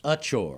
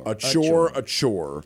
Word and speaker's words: Achor: The Samford game notes say Achor. Okay. And I'm going to trust the sports information people at Samford. – With the emphasis on the first syllable. – Yes. – So it's Achor.